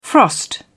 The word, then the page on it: frost: British English